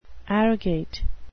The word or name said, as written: arrogate